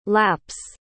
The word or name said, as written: lapps